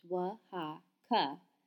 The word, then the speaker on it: Oaxaca: Oaxaca, Mexico, by Cindy Pitney. Basic information. Oaxaca, pronounced Wa-ha-ka, has been a state since 1824.